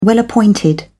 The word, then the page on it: well-appointed: British English